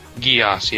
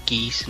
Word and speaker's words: Geass: Is that how it's pronounced? – Geass, yeah. – Yeah, because I hear a lot of people saying geese, and like...